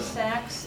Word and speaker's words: Saxe: My name is Susan Sachs. That's spelled S-A-X-E.